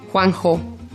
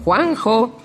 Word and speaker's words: Juanjo: Sí, Juanjo. ah, non sapete pronunciarlo? Juanjo. Quelli che si chiamano Juan José, noi li chiamiamo Juanjo.